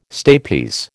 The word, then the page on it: stapes: American English